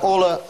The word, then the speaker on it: hola: Итак, hola – самый простой вариант приветствия. – Но H не произносится, да? – Да. Вот эта буква, по-испански она называется H, она не читается никогда.